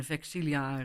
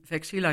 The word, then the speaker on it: Vexilla: Dat is voor sommige mensen ook wel bijzonder om te horen dat wij bijvoorbeeld ook niet uitspreken, zoals in het Italiaanse kerklatijn, Vexilia, Regis, Prodeunt, Fulget, Crucis, Mysterium. Maar wij zeggen Vexilla Regis, Prodeunt, Fulget, Crucis, Mysterium. Dus veel scherper en niet met die mooie klanken.